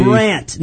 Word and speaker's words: Brandt: Does it have a silent D in it? – B-R-A-N-D-T. Brant, not Brent.